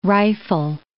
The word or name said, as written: rifle